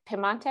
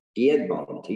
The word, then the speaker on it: Piedmont: When I was doing a little bit of research for Piedmont, am I saying that right? Is it Piemonte or Piedmont? – Piedmont.